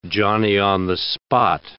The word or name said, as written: Johnny-on-the-spot